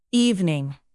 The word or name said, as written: evening